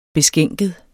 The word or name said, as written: beskænket